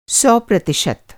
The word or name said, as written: प्रतिशत